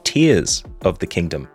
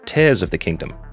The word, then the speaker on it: Tears of the Kingdom: Nintendo has come out on a stream and said that the official pronunciation of the new Zelda Breath of the Wild sequel is Tears of the Kingdom, not Tears of the Kingdom.